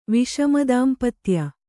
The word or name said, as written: ವಿಷಮದಾಂಪತ್ಯ